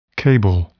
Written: cable

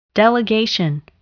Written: delegation

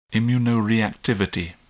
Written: immunoreactivity

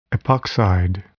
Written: epoxide

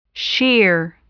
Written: shear

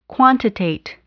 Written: quantitate

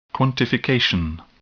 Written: quantification

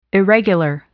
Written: irregular

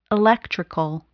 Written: electrical

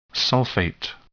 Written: sulfate

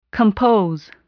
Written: compose

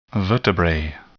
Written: vertebrae

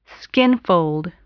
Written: skinfold

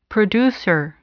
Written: producer

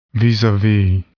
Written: vis-a-vis

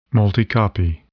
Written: multicopy

